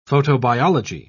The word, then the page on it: photobiology: British English